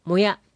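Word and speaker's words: 身舎: この図面では、もや、これは体身体の身に宿舎の舎と書いてもやと読みますが、母屋のことです。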